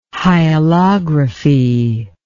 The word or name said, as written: hyalography